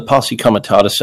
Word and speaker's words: Posse Comitatus: And the irony of this comes from something called the Posse Comitatus Act.